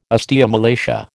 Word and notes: osteomalacia: American English

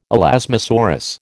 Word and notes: elasmosaurus: American English

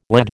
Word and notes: lad: American English

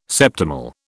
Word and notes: septimal: British English

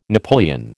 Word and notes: napoleon: American English

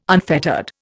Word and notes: unfettered: Indian

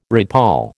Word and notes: redpoll: American English

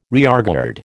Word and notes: rearguard: American English

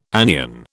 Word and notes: anion: British English

